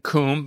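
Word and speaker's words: Cwm: The Western Kum, by the way, for listeners, that's spelled C-W-M. I know that makes no sense. Kum is Welsh for bowl, the Valley of Silence.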